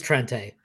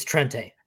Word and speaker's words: Trente: It's Trente. – And if you go to the MGo Blue site, it says Trent under the pronunciation. – Oh, he says Trente.